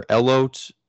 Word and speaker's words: elote: You're going to probably have to correct me on the pronunciation. Elote or elote sweet corn quesadilla. Red elote. Bliss potato pierogi. – Elote. – Elote. Wow, I was off. I really Americanized that.